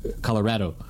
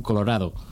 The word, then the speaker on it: Colorado: Like, for example, now that I know Spanish, it's really hard to say Colorado, because that's the wrong sound for the A. It should be Colorado in Spanish, which means, like, pink, actually.